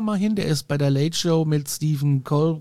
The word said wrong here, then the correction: Colbert: kann ich ihn aussprechen, im Gebäude. – Colbert, genau.